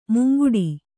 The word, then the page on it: ಮುಂಗುಡಿ: munguḍi